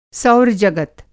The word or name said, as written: सौर जगत